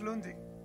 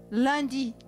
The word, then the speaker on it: lundi: And in French it's lundi. – Lundi.